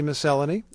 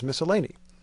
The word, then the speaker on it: miscellany: And we should mention that in Britain, they say miscellany, and here in the United States, the NPR library officially ruled that the American pronunciation is miscellany.